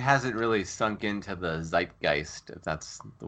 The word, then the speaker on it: zeitgeist: It annoys me when people say zitgeist. I'm glad you're not a zitgeist guy.